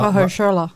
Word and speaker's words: Mahershala: Ma Mahershala.